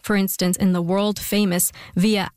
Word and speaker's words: via: Just as today, to connect cities, we have highways, from via, pronounced as via in classical Latin, and within a city, we have streets.